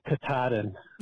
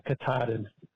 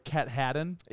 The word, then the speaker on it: Katahdin: But it's Mount Katahdin. Thanks. – Well, thank you for supporting us on Patreon. – Yeah, we're sorry, because I was calling like Cat Haddon.